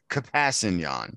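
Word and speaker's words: Kpassagnon: Yes, I will go with Tano Passanon. – Pretty close. – Yeah, that's not bad. – Good try. – Pretty close. That's not bad. The K is very quickly acknowledged in his pronunciation, though. It's Kapasanyan.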